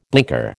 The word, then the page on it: blinker: American English